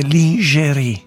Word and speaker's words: lingerie: Un altro spoof è quello della lingerie ball. – Si dice lingerie. – Ah, lingerie, lin, perché francese si pronuncia così, lin.